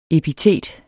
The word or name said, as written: epitet